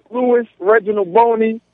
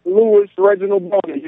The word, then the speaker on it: Louis Reginald Boney: And then go to Twitter, hit me up on my Twitter, uh, uh Reginald what, what, Louis Reginald Boney. That's that's my Twitter name at Louis Reginald Boney.